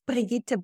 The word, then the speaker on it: Brigitte: And my name is pronounced Brigitte Bojkowszky.